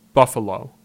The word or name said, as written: Buffalo